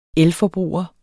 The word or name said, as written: elforbruger